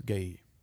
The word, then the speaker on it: Galil: By the way, it's spelled G-A-L-I-L. I think it's Gailil. – It is Gailil. – Gailil? – Yeah. Well, in France. In American, it would be Galil.